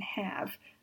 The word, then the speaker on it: have: Have, H-A-V-E, is a very prominent exception to the silent E rule. It's true that the E is silent in have, so that part works with the rule. But the pronunciation of the letter A is a, like in words without a final E, like hat and pad. It doesn't sound like the long A in words that end with E, like gave and save and wave. And also the word behave, which has the same letters H-A-V-E as have, but it is pronounced behave.